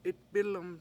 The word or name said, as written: 7itbIl1m